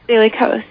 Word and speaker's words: Daily Kos: I know you've got your own website, but you also have written articles for, is it the Daily Kos? Am I pronouncing that correctly? – Daily Kos, yeah.